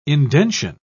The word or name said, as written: indention